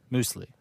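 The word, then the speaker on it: Müsli: Moosely. I'm German. – Moose. – Half of me is German. – Okay. Moosely? – Moosely. It's a German delight. – Like loosely, but yes.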